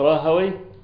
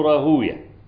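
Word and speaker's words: راهويه: راهوي إما تنطق راهوي أو راهوية، الاثنين المحدثون يقولون راهوية، وكل ما جرى هذا المجرى مثلا راهوية، زنجوية، سختوية، سعدوية، تمام؟ دي ألقاب يعني. أما اللغويون فيقولون وي، راهوي، سعدوي، زنجوي، ها كده يعني.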